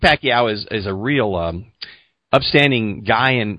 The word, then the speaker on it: Pacquiao: Pacquiao is how I've always heard.